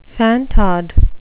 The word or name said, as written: fantod